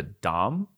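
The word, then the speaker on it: đồng: When I was in Vietnam, their currency is called the dom. It's pronounced. Mm-hmm. It's spelled like dong, which is fun, but it's pronounced kind of like dom.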